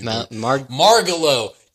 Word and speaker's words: Margalo: Sorry, this is really Margalo. – Yes, because I was like, oh, it's kind of like Margo, but like with an extra syllable for no reason.